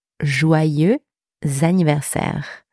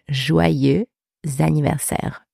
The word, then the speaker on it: joyeux anniversaire: To say happy birthday in French, we say joyeux anniversaire. Happy birthday. Joyeux anniversaire. Joyeux anniversaire. So that's the first expression. You can also say bon anniversaire, which means the exact same thing. So you've got joyeux anniversaire and bon anniversaire.